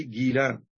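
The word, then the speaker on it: گیلان: أو بالنطق الفارسي جيلان، ولذلك تجدون من يقول الجيلاني الجيلي الكيلاني، وليس الكيلاني هي الجيلاني، لكن عدم وجود الجا في اللغة العربية يجعلونهم يكتبونها بالكاف وفوقها ثلاث نقط أو فوقها شرطة فتنطق جا، يسمونها بالعربية الكاف أو القاف المعقودة. فالشيخ عبد القادر الجيلاني رحمه الله أصله من جيلان، وهي بلاد تقع شمال إيران اليوم، ويقال لها أيضا بلاد الجيل.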